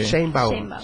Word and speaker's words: Sheinbaum: Los jóvenes en México alrededor de Claudia Sheinbaum. ¿Sí lo digo bien, eh? – Sí. Sheinbaum.